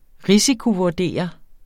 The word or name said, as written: risikovurdere